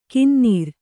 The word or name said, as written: ಗೊನ್ನೂರು